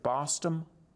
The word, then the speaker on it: Bostom: Is it Bostom? – Yes, yes.